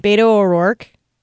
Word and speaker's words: O'Rourke: Beto O'Rourke.